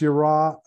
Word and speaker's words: yirah: It's yirah, yud resh aleph, or yud resh hey. The A sound at the end can be an A or an H.